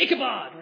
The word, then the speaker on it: Ichabod: That's how we would pronounce it in English. In Hebrew, in English, we pronounce it Ichabod, or an Ichabod Crane, we're familiar with.